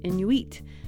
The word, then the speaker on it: Inuit: And there's a lot of diversity between Inuit communities across that massive area, like People have different ways of saying Inuit. It can also be pronounced Inuit, and you'll hear us say it both ways in this episode.